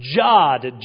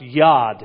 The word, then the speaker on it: Yod: We continued our study following the Hebrew alphabet, Jod, J-O-D. Actually, it's pronounced Yod, amen, J-O-D, by looking with the psalmist who was in good company.